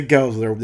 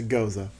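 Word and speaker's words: Gose: From the can, I can see it's spelled like rosé. – Yeah, G-O. – But it's G-O-S-E. – Yep. – So, Goza? – I have no idea. – Goza? – It's a Goza.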